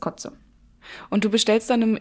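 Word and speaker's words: cozze: Also so C-O-Z-E geschrieben, glaube ich. Kotze. Wird das auch so ja. ausgesprochen? – Ja.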